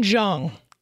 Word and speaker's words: Jeong: Ken Young? Who is the guy who plays in The Hangover? – Oh, Ken Jeong.